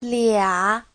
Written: lia3

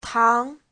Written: tang2

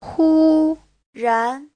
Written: hu1ran2

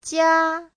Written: jia1

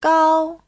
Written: gao1

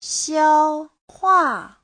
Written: xiao1hua4

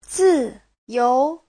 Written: zi4you2

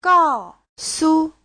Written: gao4su5

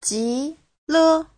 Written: ji2le5

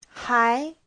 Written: hai2

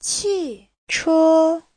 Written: qi4che1

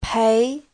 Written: pei2

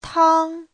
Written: tang1

